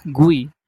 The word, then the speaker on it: GUI: This graphical user interface, or GUI, pronounced GUI, was facilitated by another concepted pioneered at Xerox PARC, bitmapping.